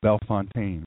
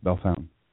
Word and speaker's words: Bellefontaine: It looks like Bellefontaine, but it's pronounced Bellefontaine.